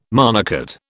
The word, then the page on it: monocot: British English